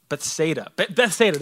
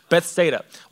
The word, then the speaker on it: Bethsaida: Bethsaida, that's how you say it. I think that's how you say it. That's how I looked it up. Bethsaida, while he sent the people home.